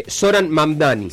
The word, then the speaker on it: Zoran Mamdani: Mahdami. Lo voy a decir mal, pero porque es difícil de pronunciar. Pero no tengo internet, así que no te puedo comprar. – Es un opositor acérrimo de Donald Trump. Zoran Mamdani.